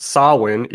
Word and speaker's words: Samhain: Um, it's pronounced Samhain, even though it's spelled S A M H A I N.